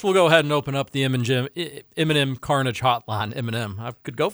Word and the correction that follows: Cartage: I did pronounce that correctly, right? – M&M Carnage. – M&M Carnage. – I think it's a T. Cartage. – Cartage. – Yeah. – Like, not pure carnage.